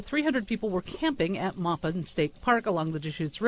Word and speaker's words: Maupin: Am I pronouncing it? Is it Maupin? Maupin? – Maupin. M-A-U-P-I-N? – Yeah. – I think so, Maupin.